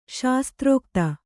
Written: ಶಾಸ್ತ್ರೋಕ್ತಿ

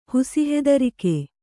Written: ಹುಸಿಹೆದರಿಕೆ

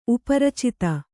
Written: ಉಪರಚಿತ

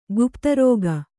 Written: ಕೋಪೋದ್ರೇಕ

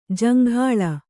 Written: ಜೊಂಗುಳಿ

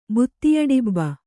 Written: ಬುತ್ತಿಯಡಿಬ್ಬ

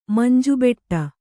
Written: ಮಂಜುಬೆಟ್ಟ

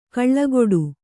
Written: ಕಳೆಗೊಡು

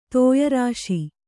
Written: ತೋಯರಾಶಿ

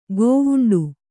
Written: ಗವುಂಡಿ

